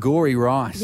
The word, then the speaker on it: Angouri Rice: Angouri Rice.